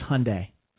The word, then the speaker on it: Hyundai: It's Hyundai. So Hyundai. – Even, Hyundai. Hyundai. Rhymes with Sunday.